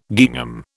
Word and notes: gingham: British English

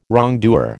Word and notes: wrongdoer: American English

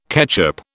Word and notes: ketchup: British English

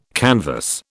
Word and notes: canvass: British English

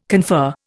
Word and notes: confer: Indian

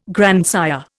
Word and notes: grandsire: Indian

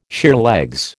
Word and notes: sheerlegs: American English

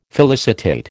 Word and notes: felicitate: American English

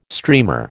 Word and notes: streamer: American English

